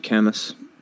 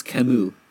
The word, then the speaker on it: Camus: I'm kind of enraged that you pronounced it like that. I mean, well, that's how it's fucking spelt, bro. – Yeah, but he's French, so it's pronounced Camus.